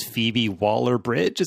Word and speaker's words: Phoebe Waller-Bridge: It's written by the lady that did Fleabag, and I want to say her name is Phoebe Waller-Bridge. Is that right? – Correct.